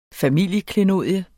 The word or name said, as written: familieklenodie